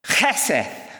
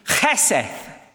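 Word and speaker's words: cheseth (חסד): The word is cheseth. Cheseth. C-H as in loch. E-S-E and a D that sounds a bit like a T-H. Cheseth.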